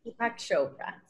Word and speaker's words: Deepak Chopra: que es J Balvin y Deepak Chopra. ¿Lo dije bien? Deepak Chopra, sí.